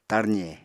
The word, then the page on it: tarze: Apatani